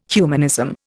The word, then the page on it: humanism: Indian